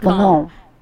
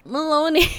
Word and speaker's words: Malone: Malone. It's just Malone. Why is it just Malone? Why is it not Maloney? English is weird like that, guys.